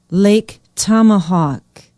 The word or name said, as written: Lake Tomahawk